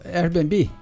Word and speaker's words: Airbnb: O Airbnb? Como é que fala? – No Airbnb.